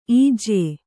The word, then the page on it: ಇಜ್ಯೆ: ijye